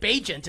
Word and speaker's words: Bagent: I actually did my show on WFAN earlier today and got a note from a prominent NFL reporter who will remain nameless saying that Bagent is the correct pronunciation for Chicago's starting quarterback.